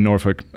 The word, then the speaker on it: Norfolk: So Richmond's where you grew up, but then you went to college at Old Dominion, which is in Norfolk, which is, am I saying that correctly, by the way? I've heard it said a couple different ways. – I say Norfolk.